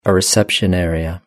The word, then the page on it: a reception area: British English